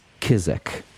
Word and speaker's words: XKSZX: You know that this village is spelled in your language XKSZX. Manted is a very complicated language. It's mostly buzzes and clicks, and it's lots of fricatives and mostly sibilants. The closest way that you can pronounce this, the name of this village, this small town, is Kizik.